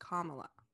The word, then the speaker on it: Kamala: her name is actually pronounced Kamala.